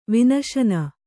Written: ವಿನಶನ

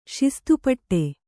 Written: ಶಿಸ್ತುಪಟ್ಟೆ

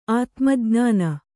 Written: ಆತ್ಮಜ್ಞಾನ

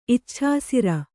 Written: ಇಚ್ಛಾಸಿರ

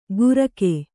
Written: ಗುರಕೆ

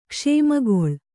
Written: ಕ್ಷಮೆಗೊಳ್

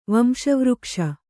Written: ವಂಶವೃಕ್ಷ

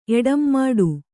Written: ಎಡಂಮಾಡು

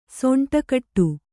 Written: ಸೊಂಟಕಟ್ಟು

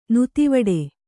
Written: ನೀತಿವಿಡಿ

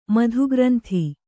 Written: ಮಧುಗ್ರಂಥಿ

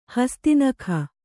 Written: ಹಸ್ತಿನಖ

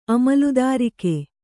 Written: ಅಮಲುದಾರಿಕೆ

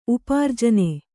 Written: ಉಪಾರ್ಜನ

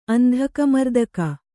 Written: ಅಂಧಕಮರ್ದಕ

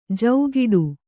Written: ಜೌಗಿಡು

